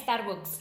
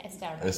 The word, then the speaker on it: Starbucks: como, no se dice Starbucks, se dice Starbucks.